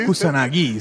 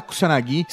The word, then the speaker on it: Kusanagi: mandar mensagem criticando a pronúncia. É porque Kusanagi seria, na verdade.